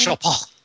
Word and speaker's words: Chopin: Chopin.